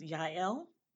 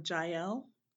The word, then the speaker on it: Yael: I realized that my name had two syllables and that actually the J in Hebrew should be pronounced with a different sound. So really my name is Yael. Or Jael,